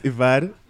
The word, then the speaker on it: Evad: Because my name is Ivad. It's Dave backwards.